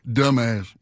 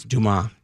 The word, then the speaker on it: Dumas: I personally always laugh at the guy who mispronounced Alexandra Dumas' name, and I start giggling. – Dumbass. – Yeah, Dumas. Yeah. Dumas uh, uh, the characters in Shawshank, every everybody's perfect in Shawshank.